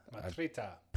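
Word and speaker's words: Mitriță: So, you know, the team is playing very well, and that brings us to Alexandru Matria. – Matrita. – Probably butchered that, and you nailed that.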